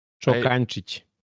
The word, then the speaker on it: Čokančić: Čokančić, if you can pronounce it. – Čokančić. – Ah, almost perfect. Yeah, you got it almost there.